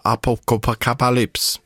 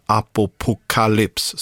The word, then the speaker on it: Apocalypse: Innan dess, bandet som är lite halvkul att uttala, Apocalypse. Ja, nej, inte riktigt så, men Apocalypse, så ska man säga.